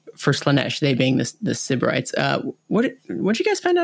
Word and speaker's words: Sybarites: Am I saying that right? Sybarites? Sybarites? – I'd say Sybarites is how it is. – Sybarites, yeah.